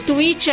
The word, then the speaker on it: Twitch: O tweet é, não é o Twitter, tá? É a plataforma do streaming que se escreve T-W-I-T-C-H.